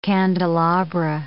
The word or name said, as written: candelabra